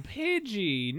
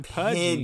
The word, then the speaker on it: Pidgey: Oh, Pidgey, not Pudgy. – Pidgey. Yes, there's you it's know... an I, not a U.